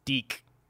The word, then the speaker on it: Dick: It's just Dick. That's your whole name. Dick. It's Deek. It's Deek. Well, how am I supposed to do that? It's spelled Dick.